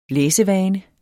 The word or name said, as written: læsevane